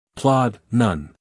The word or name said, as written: plod